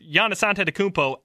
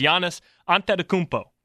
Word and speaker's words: Giannis Antetokounmpo: And yes, that is how you pronounce his name. I watched videos on videos of him pronouncing his own name. It is Giannis Antetokounmpo, the Greek Freak.